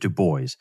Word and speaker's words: Du Bois: Du Bois, who, by the way, just for anybody listening, that is how he pronounced his name. He pronounced it Du Bois.